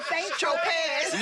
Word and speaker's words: Saint-Tropez: That Z is silent? – Saint-Tropez. He said Saint-Tropez.